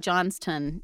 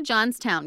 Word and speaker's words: Johnstown: And I'm hoping it's not like Johnston, even though there's a W Johnston. – In it. So you know how sometimes East Coast things trend British in pronunciation? – Yeah. – Yeah. So I'm going to say Johnstown because we live in Texas.